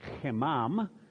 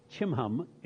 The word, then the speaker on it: Chimham: But behold, my servant, in Hebrew you would pronounce it chimam, looks like chimam, chimham in English.